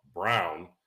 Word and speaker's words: Braun: You have Jokic, you have Jamal Murray, you have Aaron Gordon, you have Michael Porter Jr., you have Christian Brown, spelled like Braun, Christian Brown, who could take over Bruce Brown's position.